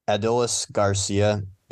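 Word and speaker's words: Adolis García: It's not a super hard one, but it's Adolis Garcia.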